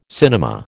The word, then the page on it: cinema: American English